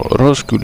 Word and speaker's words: Roscoe Chenier: Πάμε λοιπόν να, λίγο πριν κλείσουμε, να ακούσουμε Roscoe Chenier πάλι. Έχουν κάτι περίεργα ονόματα, Έλεος δηλαδή. Εγώ αυτό το πράγμα, R-O-S-E-O-E, αυτό το πράγμα εγώ δεν μπορώ να το προφέρω. Roscoe λέγεται, Roscoe Chenier, Chenier, τη στιάση το μπουκατοκόφινο.